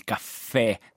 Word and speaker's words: caffè: ma anche altre parole come ad esempio il caffè, sentite che c'è accento aperto, quindi abbiamo l'accento grave. Te lo pronunciamo aperto, quindi ha l'accento grave.